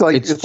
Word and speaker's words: Jeymes: 2021's The Harder They Fall, directed by Is it just James? Am I saying that right? J-E-Y-M-E-S, James Samuel? – It's James.